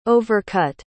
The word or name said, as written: overcut